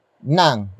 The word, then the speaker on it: ng: Up next we have nang, which is spelled as ng.